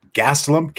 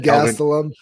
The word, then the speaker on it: Gastelum: Next up was a main event, Jared Cannonier and Kelvin Gastelum. Kelvin, did you see that? Did you catch that? Did you catch that, Zach? – Good job. You said his name right.